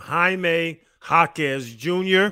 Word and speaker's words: Jaime Jaquez Jr.: I'm going to say his name slow because I mess it up all the time. Jaime Jaquez Jr. Very good.